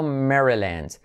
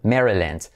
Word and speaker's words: Maryland: Number 24, Maryland, pronunciado Maryland. Mary, letra clave R, or Mary, y como E, land, letra clave L. Y la vocal A como E, Maryland.